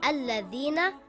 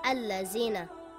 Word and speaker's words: Alladhina: Or this word is pronounced Alladhina and not Allazina.